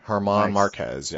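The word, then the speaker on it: Germán Márquez: For the Diamondbacks, we got Zach Greinke, the right-hander, and is it Herman Marquez? – Herman Marquez. Yes. – There we go. See, the G is silent.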